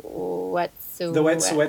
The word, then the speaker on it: Wet'suwet'en: Wet'suwet'en.